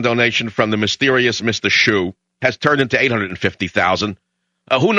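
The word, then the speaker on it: Hsu: Now, it's normally you would think it's spelled S-H-O-E hyphen gate, but it's H-S-U gate.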